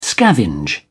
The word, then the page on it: scavenge: British English